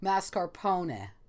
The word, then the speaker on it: mascarpone: Yeah, technically, it's exactly how you said it, Fletcher. It is mascarpone. It's like a little ne.